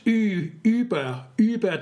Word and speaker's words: über: Yeah, it's pronounced über 30, über 20.